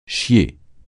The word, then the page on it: chier: français